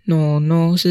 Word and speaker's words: 懦懦: 诺诺那个诺诺的音是来自于懦懦弱的那个懦，一个心在右边，一个雨水的雨，下面一个而已的而。诺诺是他这个懦的一个台语发音。